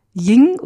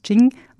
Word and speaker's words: Jing: Das Yin und das Yang, die schreibt man beide mit Y und das Ying oder Jing schreibt man mit dem, schreibt man mit J und am Schluss mit einem G, das Jing.